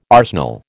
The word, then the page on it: arsenal: American English